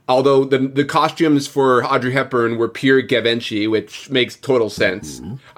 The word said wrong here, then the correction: Givenchy: I don't know if I pronounced that right. Is it Gavinci? – I thought it was Givenchy.